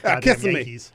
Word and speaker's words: Kissimmee: You know, we pronounce it up here Kissimmee. – Yes, we do. – That's not how it's pronounced. – We also I know it's Kissimmee. – You guys are doing it wrong. – I know. – We don't care enough to say it the right way.